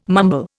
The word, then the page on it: mumble: Indian